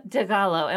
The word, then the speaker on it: DiGallo: I would like to welcome Emma DiGallo.